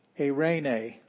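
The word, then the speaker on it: Eirene (εἰρήνη): Eirene, that means peace. So this is word. Eirene, that just means peace. We have a diphthong that counts as one, not two.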